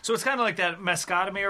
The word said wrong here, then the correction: mastectomy: Mastectomy? – Yes.